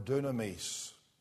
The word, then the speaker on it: dunamis: It is the word dunamis. And actually, that Greek word gives us the English word dynamite, as you might notice, because dynamite's virtually a transliteration of the Greek word dunamis.